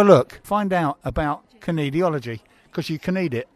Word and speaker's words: kinesiology: Tell me, kinesiology. It's like knees with pronouncing the K, isn't it? Yeah, kinesiology.